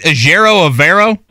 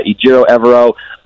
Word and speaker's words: Ejiofor: Ejiofor is sort of how we've come to know that his name is pronounced. But yeah, it took us all out here just a little bit to get that right.